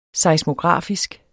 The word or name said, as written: seismografisk